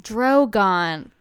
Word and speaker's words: Drogon: Dragon? Dragon? – You just said Dragon. – I did, because what's his name? – Drogon. – Fuck. Okay. Yeah. – Dragon is just a mispronunciation. It's a dragon.